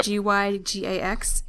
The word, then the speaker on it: Gygax: Gary Gygax, and I'm sure I'm pronouncing that wrong. – No, that's right, exactly right. – It is? Okay, yep. Gygax.